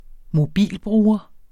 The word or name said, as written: mobilbruger